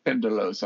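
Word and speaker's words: Tindalos: Yeah, we're going to talk a little bit about the Hounds of Tenedalos. – Ooh, all right. I like the way that's pronounced. Okay, so we'll talk to you in a bit, everyone. – I guess it's Tendalos. I always mispronounce it.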